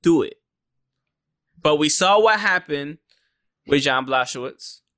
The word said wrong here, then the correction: Błachowicz: or Błachowicz. I should say I've been saying his name wrong the whole fucking time.